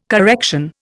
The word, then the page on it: correction: Indian